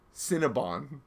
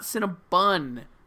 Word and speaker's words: Cinnabon: Cinnabon. – Yes, Cinnabon. Okay. Not Cinnabun, idiots. – It should be Cinnabon.